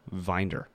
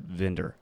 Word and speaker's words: Vinder: I pronounced it originally Vinder, but as soon as I got it printed and gave it to the community, everyone pronounced it Vendor. And I figured, well, it's easier for me to change how I say it than change the people around me. – Okay, and so it's spelled V-I-N-D-E-R.